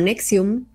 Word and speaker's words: NXIVM: que se llama Nexium, se escribe Nxivm, y digo cómo se escribe